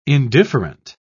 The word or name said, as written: indifferent